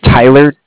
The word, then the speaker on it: Tyler: My name is Tyler, T-Y-L-E-R. – Mm-hmm. – And I'm calling with Cardiff. It's spelled C-A-R-D-I-F-F as in Frank Frank.